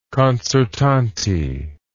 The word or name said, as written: concertante